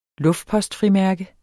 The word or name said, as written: luftpostfrimærke